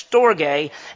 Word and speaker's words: storge: That's how it's pronounced. It's storge.